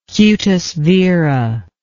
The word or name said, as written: cutis vera